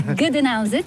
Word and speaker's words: Gdańsk: Gdańsk, c'est le nom slave.